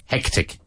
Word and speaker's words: hectic: No, you must pronounce heck. – Het. – Like, here you have the name Hector. So the first syllable with pronouncing the H. Heck. – Hectic.